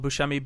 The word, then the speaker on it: Buscemi: My last name is spelled Buscemi, B-U-S-C-E-M-I.